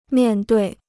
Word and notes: 面对: miàn duì

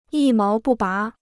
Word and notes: 一毛不拔: yī máo bù bá

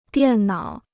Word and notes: 电脑: diàn nǎo

